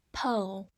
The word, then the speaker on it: pole: pole，p o l e，pole。字母 o 发它本身的音，末尾 e 不发音。 pole，它是名词，表示那种细长细长的杆子或者是极点的意思。